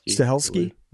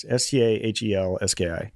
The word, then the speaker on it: Stahelski: Do you think if Stelsky, is that how you pronounce his name, his last name? Stahelski. There it is. Stahelski. Yeah, Stahelski.